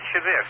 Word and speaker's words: Shavit: Did you hear the part about the Shavit rocket from Israel? Were you listening to that? – Yes, I did. You pronounced it right, Shavit.